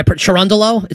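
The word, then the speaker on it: Cerúndolo: Putting in perspective, Yannick Sinner against Juan Michael Cirundolo, right? Am I Cirundolo? It's Cirundolo.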